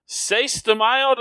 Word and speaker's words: Seis de Mayo: Seis de Mayo, did I say that right, Mike? – Yes, you did.